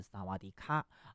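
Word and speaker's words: 萨 瓦 迪 卡 (สวัสดีค่ะ): 你一定很熟悉，因为如果加上卡，就是萨瓦迪卡，你好，对不对啊？但其实呢，这个泰语哈、似乎有这个阴性阳性的这个区别，女生啊讲你好是萨瓦迪卡啊，但是男生呢啊，这个发音要是萨瓦迪 club 啊。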